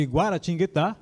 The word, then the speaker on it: Guaratinguetá: direto de Guaratinguetá.